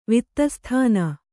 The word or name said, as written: ವಿತ್ತಸ್ಥಾನ